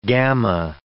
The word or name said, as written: gamma